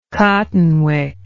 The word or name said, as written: cottonwick